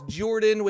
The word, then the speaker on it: Jordan: My name is Jordan with a silent PH in the middle, and I'm joined always by my wonderful co-host, Sir Lucian, over at Sir Lucian Gaming.